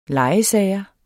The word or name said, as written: legesager